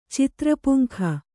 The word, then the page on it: ಚಿತ್ರಪುಂಖ: citra puŋk'ha